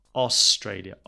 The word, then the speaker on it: Australia: Also, pronunciation Australia, not Australia or Australia, it's Australia. Australia.